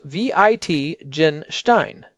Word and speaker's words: Wittgenstein: Sixth term, Wittgenstein. Pronounced V-I-T-Gen-Stein.